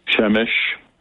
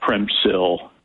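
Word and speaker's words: Przemyśl: There's a train station very close to the border in a town called Chemish. It's spelled Premsyl, in American, but it has nothing to do with the way it's pronounced, you know. So, but you'll see that Premsyl, people will call it.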